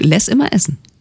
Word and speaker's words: Les Immer Essen: weil dieses Les wird geschrieben L-E-S, hätte ja auch Le immer essen heißen können, also französisch oder Les oder so. Nein, es heißt, also er hat jedenfalls es so ausgesprochen, Ingolf Lück, Les immer essen.